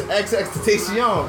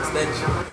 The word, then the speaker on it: XXXTentacion: How you pronounce his name? – It's XXXTentacion, bro. – XXXTentacion. I was calling him extension.